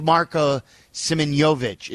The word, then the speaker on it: Simeonovic: And I believe Marko Simeonovic is how you say the second-round pick's name.